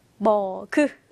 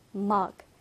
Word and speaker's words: mug: 자, 그런데 우리말로는 몇 음절이에요? 머그. 그렇죠. 머그 이렇게 이음절인데 영어 발음은 mug, mug이죠.